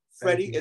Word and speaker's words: Freddie: Freddie is spelled with an IE because I am individually exclusive.